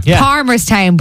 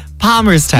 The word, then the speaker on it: Palmerstown: Palmerstown, but the L is silent. – Palmerstown. – You just say Palm Sunday.